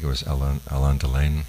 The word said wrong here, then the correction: Delon: How do you pronounce his name? Delon.